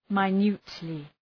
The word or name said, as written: minutely